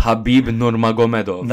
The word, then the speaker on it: Khabib Nurmagomedov: Khabib Nurmagomedov.